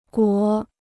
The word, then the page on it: 国: guó